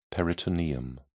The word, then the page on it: peritoneum: British English